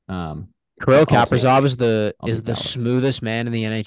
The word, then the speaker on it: Kaprizov: Is that how I say his name? – I think it's Kaprizov, yeah.